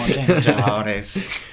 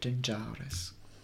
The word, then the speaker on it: Jean Jaurès: Oui, j'ai fait occitan, j'aime bien le son occitan Jean Jaurès. Le premier d'Occitanie, donc je vous le dis, c'est ça le nom original, sa prononciation originale. – Oui. – Jean Jaurès.